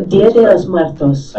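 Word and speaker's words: Día de los Muertos: We were going to experience New Orleans on Halloween and the 2 days after, the two following days, which are Dios de los Muertos. Am I saying that right? – No, you are not. – No, Dia de los Muertos.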